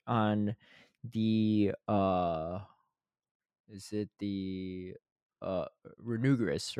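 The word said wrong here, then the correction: Runerigus: Yeah, Renurigus.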